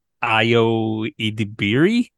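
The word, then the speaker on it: Ayo Edebiri: Is it Ayo Idibiri? Is that how you pronounce her name? – Yes, I think that's correct, and we apologize.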